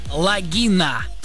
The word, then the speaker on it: логина: Термин уже занесен во все словари. И ударение в нем там стоит на втором слоге. Поэтому правильно говорить логина.